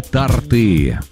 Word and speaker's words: торты: Куда ставить ударение? Торты или торты? – Торты. – Тортов или тортов? Как мы все-таки... – Тортов. – То есть торты в первом случае, а во втором случае тортов.